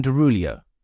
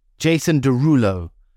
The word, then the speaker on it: Derulo: How's it pronounced? – It's Derulo, bro. – Jason Derulo.